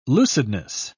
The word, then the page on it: lucidness: British English